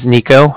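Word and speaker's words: Niko: My name is Nico, and that's N-I-K-O. – Okay. – And then the Bank of Cardiff is spelled C-A-R-D-I-F-F, as in Frank Frank.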